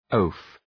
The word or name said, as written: oaf